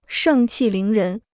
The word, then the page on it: 盛气凌人: shèng qì líng rén